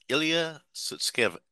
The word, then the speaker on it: Ilya Sutskever: It's Ilya Sutskever? Probably just butchered that. – Ilya Sutskeva.